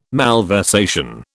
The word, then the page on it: malversation: British English